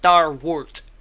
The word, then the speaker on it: starwort: Not Starburst, not Stardust, Starwort.